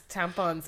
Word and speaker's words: tampons: but she didn't know how to pronounce tampons, and she would call them, what is it again? – Tampons. – Tampons. – Why did she go tampons? – She didn't know where to stress.